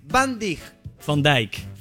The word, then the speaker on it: Van Dijk: Van Dijk.